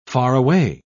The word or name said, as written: faraway